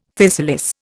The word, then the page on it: physalis: Indian